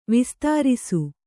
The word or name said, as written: ವಿಸ್ತಾರಿಸು